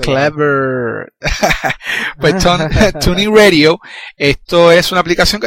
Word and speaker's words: TuneIn: se llama Tunnel, así que se pronuncia. – No, TuneIn Radio.